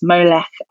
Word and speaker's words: Molech: which within ancient Greek translations of the text have tended to be pronounced Moloch. In the Masoretic text, it's often pronounced Molech.